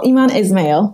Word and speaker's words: Eman Ismail: Am I saying that right? Eman Ismail? – You are. Well, Eman Ismail.